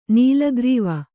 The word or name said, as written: ನೀಲಗ್ರೀವ